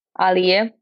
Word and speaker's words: Aliya: Aliya, it's still the same. It's like very convenient international name, so you can pronounce it the way you and the way it sounds in English.